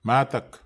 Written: matak

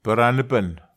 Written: peranepen

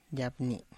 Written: yabni

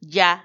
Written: ya

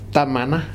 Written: tamana

